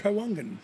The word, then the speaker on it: Trawangan: Trawangan.